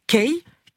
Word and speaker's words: Kae: Kay, K-A-E, se prononce comme la lettre K en anglais.